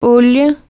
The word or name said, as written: aceite